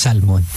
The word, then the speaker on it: salmon: That is pronounced with an L, love, with an L. Okay. – Salmon? – Yeah, it's salmon.